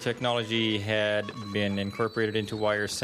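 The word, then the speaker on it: WIR: So the three would soon regroup as were, still pronounced Wire, but before the official announcement of Go To Bed's departure, Wire would put out the album The Drill.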